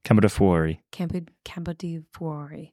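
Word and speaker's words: Campo de' Fiori: Campo di Fuori. No, I think you actually pronounced it considerably better than me. Campo di Fuori.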